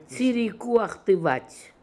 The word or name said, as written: Tsirikooakhtivat